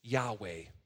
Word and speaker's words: Yahweh: In Hebrew, it's pronounced like this, Yahweh.